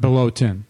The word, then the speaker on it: Bolotin: How did you say it? How was it? It was B-O-L-O-T-I-N. – Right. Bolotin. – Bolotin.